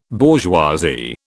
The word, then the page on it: bourgeoisie: British English